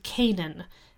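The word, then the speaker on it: Cainan: Then we can skip over to chapter 8 of Jubilees, and we have a character named Canaan.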